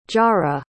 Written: jarah

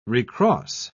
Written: recross

